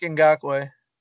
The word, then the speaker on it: Ngakoue: I'm going to butcher this name. Yannick Ngakoue, right? – Yannick Ngakoue. – Okay.